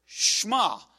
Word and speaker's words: Shema: But it's the Shema, and it's because the actual first word for listen or hear is the Hebrew word Shema. Shema, O Israel, is the way it's pronounced.